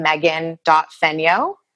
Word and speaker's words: Megan Fenyoe: It's just my name, Megan.Fenyoe, MEGAN.FENYOE.